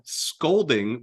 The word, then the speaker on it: scalding: Scalding would be if the shower like wagged its finger at you and said, never do that again. You're a very naughty boy. That is scalding.